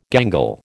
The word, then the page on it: gangle: American English